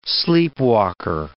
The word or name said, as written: sleepwalker